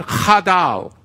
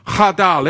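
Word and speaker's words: Chadal (חדל): This is biblical, because the word here in verse 30 of Jeremiah chapter 51 for forborn is the Hebrew word chadal. Chadal.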